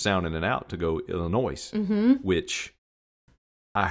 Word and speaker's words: Illinois: Illinois, yep.